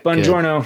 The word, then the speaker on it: Bongiorno: That's right. Mike Bongiorno. Good. It's Bongiorno. Good morning in Italian.